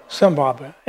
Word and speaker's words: Zimbabwe: One day, Gaylord was there in Zimbabwe. How do you pronounce that? – Zimbabwe.